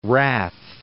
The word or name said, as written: Wrath, Cape